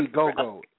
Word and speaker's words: Indiegogo: he had the information in on Indigo. Is this how you pronounce it? And then another Indiegogo, right.